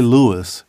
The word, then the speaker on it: Louis: Now, I say Louis because I know the prominence of syllables in Black language and where we decide to drop a syllable at the end of a word and when we decide to enunciate every part of someone's name because of the respect we have for it, right?